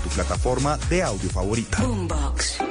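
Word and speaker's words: Bumbox: Nos pueden encontrar también en las redes como Boombox, se escribe B U M B O X, B U M B O X Boombox, así todo pegadito y se van a encontrar con todos estos podcasts y con todos esos podcasters.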